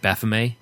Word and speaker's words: Baphomet: including this like Bahume, uh, bah- I forgot how to say it, Baphomet, Baphomet, Baphomet uh, incense burner where the smoke comes out of his nose and uh, that sort of thing.